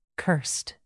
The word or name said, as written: cursed